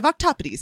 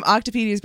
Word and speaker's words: octopodes: It's the Greek pronunciation of plural octopus. Octopodes. Oh. I love it. I love calling them octopodes. For a long time, I was calling them octopedes, but I was doing the wrong pronunciation.